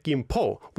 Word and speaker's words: Gimpo: As you said, Busan was P-U-S-A-N, not B as it is now. Gimpo, K-I-M-P apostrophe. Okay, Gimpo. Emphasizing there's these two syllables. – Okay. – Gimpo. That's where there's like a hard end to the first syllable. – Wow. But that's not split the same way as the Hangul, I suppose. Gimpo. – Right. – It would be before the P.